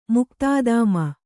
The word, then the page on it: ಮುಕ್ತಾದಾಮ: muktādāma